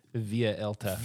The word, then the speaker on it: Vuelta: It's pronounced Via Elta.